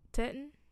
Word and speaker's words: Teton: It's spelled T-E-T-O-N. – Yeah, I thought it was Teton. – Teton? – Yeah, that's how you swallowed the end of it. That's how I always said it.